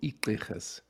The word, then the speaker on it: Igqirha: And in the Xhosa tradition, Sangomas are called Igqirhas. So I'm part of a tribe or community of Amagqirha.